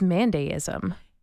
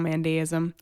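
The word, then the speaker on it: Mandaeism: We'll have to do a deep dive on Mandaism, which we might be mispronouncing. It looks like it's pronounced Mandaism.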